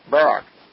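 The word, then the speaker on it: Barrack: And now we're back with the author of the book, Unsung Davids, Ben Barak.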